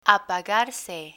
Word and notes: apagarse: spanish

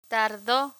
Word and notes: tardo: Spanish

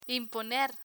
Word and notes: imponer: spanish

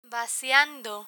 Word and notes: vaciando: Spanish

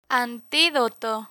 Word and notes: antidoto: spanish

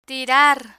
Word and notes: tirar: spanish

